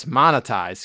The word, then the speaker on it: monetize: And a bunch of other shit, I'm sure, that, you know, would, you know, turn monotize. Your skin green. – It's monetized, Squeezer. – Yes. – Montanize. Monetize. Squeezer, monetize is the word we're looking for.